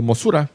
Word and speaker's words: Mosura: El debut de Mothra, o Mosura, como se lo pronuncian los japoneses, fue en 1961.